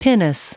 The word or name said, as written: pinnace